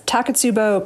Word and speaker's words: Takotsubo: So Takotsubo disease, is that? – Yes, Takotsubo cardiomyopathy.